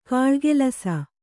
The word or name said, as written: ಘಲಘಲಿಸು